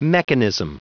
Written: mechanism

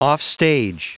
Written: offstage

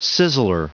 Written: sizzler

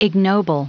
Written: ignoble

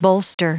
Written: bolster

